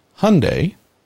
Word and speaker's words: Hyundai: The correct pronunciation of the brand's name, according to the brand, is Hyundai, like Sunday.